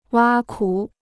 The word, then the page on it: 挖苦: wā kǔ